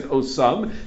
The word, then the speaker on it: osam: So the word osam is written without a vav. We just pronounce it osam.